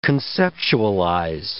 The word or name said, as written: conceptualize